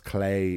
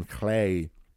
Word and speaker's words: Lleu: Even Clay, which you might not be able to tell, probably won't be able to tell from how I'm pronouncing it, and I hope I'm pronouncing at least that okay, is spelled L-L-E-U. So even in spelling, it's spelled very like the L-U-G-H of Lu Lavada.